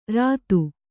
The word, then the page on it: ಋತಿ: Kannada